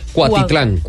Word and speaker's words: Cuautitlán: Ford anunció paros en su producción hasta el próximo lunes en sus plantas de Cuautitlán. ¿Lo dije bien? – Eh, Cuautitlán.